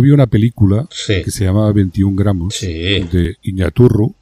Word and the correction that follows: Iñárritu: o algo así. lo pronuncio fatal, lo sé. – No, es tan difícil, pero bueno, se puede decir bien. ¿eh? ¿Cómo es? – Iñárritu.